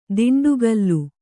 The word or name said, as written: ದಂಡಿಕೋಲು